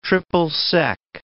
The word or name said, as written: triple sec